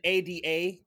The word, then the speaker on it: Ada: It's going to be nice to talk some After Yang, another A24 movie with an ADA character, this time pronounced Ada in this movie.